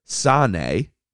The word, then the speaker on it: sane: Sane, spelled S-A-N-E, and Maas, that's spelled M-A apostrophe A-S.